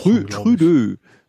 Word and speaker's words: Trudeau: Ähm, Trudeau hat nochmal, ist nochmal Trudeau. Fran Französisch ausgesprochen. – Trü Trudeau.